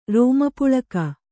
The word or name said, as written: ರೋಮಪುಳಕ